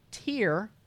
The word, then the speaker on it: Tyr: So this handsome boy named Tyr. – Son of Odin, not Thor, but Tyr. Tyr is another son of Odin. – T-Y-R. So when you saw the word T-Y-R, did you know?